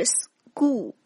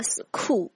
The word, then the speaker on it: school: 比如说最常见的哈，学校 school, 它的发音是 school, 而不是音标当中所写的 school。